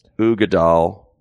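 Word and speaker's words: Uigeadail: Well, the full title is pronounced Ardbeg Ugadal.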